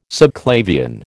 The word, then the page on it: subclavian: American English